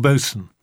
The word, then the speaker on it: Boatswain: He had a dog called Boson, spelt boatswain, but pronounced Boson.